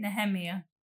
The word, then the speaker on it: Nehemia: Nehemia.